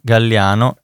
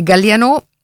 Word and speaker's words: Galliano: E Duo Gardel, musiche di Brahms, Piazzolla, Gardel, Ubay, Galliano e Rachmaninov. – Ok, ti aggiusto un po' le pronunce dei compositori. È Galliano, Gardel e Rachmaninov.